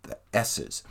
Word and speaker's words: Esses: Yeah, pretty cool EP there from The S's. And that, by the way, is spelled E-S-S-E-S, The S's.